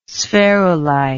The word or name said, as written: spherulite